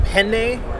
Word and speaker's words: penne: Because if you ask for a penne arrabbiata, if you ask for a penne, you put the emphasis on the N, that's the pasta.